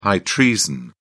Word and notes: high treason: British English

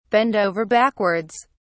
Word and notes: bend over backwards: British English

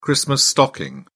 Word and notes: christmas stocking: British English

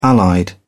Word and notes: allied: British English

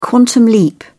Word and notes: quantum leap: British English

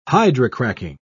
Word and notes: hydrocracking: British English

